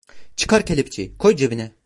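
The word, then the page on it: koy: Turkish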